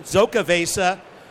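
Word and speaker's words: Xocoveza: This is our Chris Baker Insurgente Stone Zocca Vesa. If you don't know how to pronounce it, say it with the Zocca, Z, Zocca Vesa Mocha Stout.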